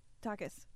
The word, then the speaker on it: Takis: It's the first way you said it, Bryn. The first way you said it. – How do you say it? – Takis. – Takis. – Not Takis.